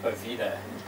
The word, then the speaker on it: Bovida: So I was like, okay, well, camera crew, and I held up my lanyard, and I said, how do you say this? And I said, Bovida. And I was like, okay, there we go.